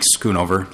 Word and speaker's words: Schoonover: My name is Mike Schoonover. Last name is spelled S-C-H-O-O-N-O-V-E-R. – There, thank you. – You're welcome, thank you. – Mr. Schoonover.